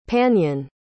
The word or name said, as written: Panyin